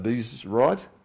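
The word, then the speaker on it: Abiezrite: Now the angel of the Lord came and sat under the terebinth at Ophrah, which belonged to Joash the Abizrite. There you go. That's a proper way to say it too, by the way.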